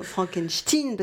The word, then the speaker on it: Frankenstein: Il insiste pour qu'on prononce son prénom Frankenstein parce qu'il veut se distancier.